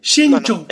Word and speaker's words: Shenzhou: Shenzhou. – La nave Shenzhou. ZH es una CH muy suave. Pasa que está, hay gente que lo pronuncia como una J en inglés.